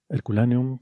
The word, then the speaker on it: Herculaneum: Herculaneum, ¿cómo se pronuncia esto, eh, María? – Lo has dicho muy bien.